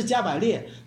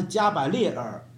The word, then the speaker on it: 加百列尔: 第四个叫米迦尔，我叫有人叫米迦勒哈，有人还有一个是叫做萨拉夸尔，还有一个叫加百列尔，本来是加百列，但是准确的发音是加百列尔，有一个神在后面的那个后缀。